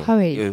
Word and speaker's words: 화웨이: 예, 창립을 했었는데 이름이 좀 애매하지 않아요? 화웨이. – 발음이 좀 어려워요. 예, 화웨이.